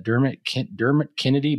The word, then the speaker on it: Dermot Kennedy: And she paired it with Dermot Kennedy Better Days.